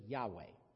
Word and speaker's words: Yahweh: The English pronunciation is Yahweh.